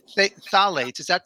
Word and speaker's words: phthalates: I mean, before we went on the air, we mentioned pesticides, and I've heard you on other podcasts talk about phthalates. Am I pronouncing that correctly? – Phthalates? Yes, that's correct.